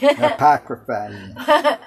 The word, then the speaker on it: Apocrypha: did do you remember that the Apocrypha did I say that right? – Apocrypha was removed from the King James Version.